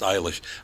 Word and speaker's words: Eilish: It doesn't say how they pronounce it, but her name is pronounced Eilish. Another guy, K-E-L-C-E. How would you pronounce that? – Kelsey? – Kels. – K-E-L-C...